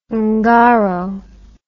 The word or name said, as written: ungaro